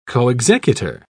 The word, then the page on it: coexecutor: British English